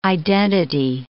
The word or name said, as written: identity